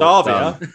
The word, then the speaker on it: Savea: Is it Savea? – It is Ardi Savea. Well Savea? Done. That's how the Kiwis say it. – Then they play football in Prague. – Savea.